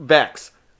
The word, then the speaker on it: Vex: Like, Vex. I did say it right.